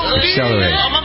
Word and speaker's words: Accelerate: She previewed the LP with the Kanye West-produced single Accelerative. Accelerate. How did I say it? Accelerative? No, I think so. Accelerate.